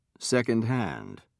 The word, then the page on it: secondhand: British English